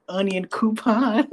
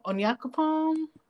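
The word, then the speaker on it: Onyankopon: Somebody on TikTok pronounced his name Onion Coupon. – Onion Coupon. How do you pronounce it? – Onyakupon? – Onyakupon. – But they say Onion Coupon.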